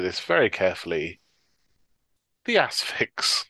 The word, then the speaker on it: Asphyx: There's the correct pronunciation is Asphyx.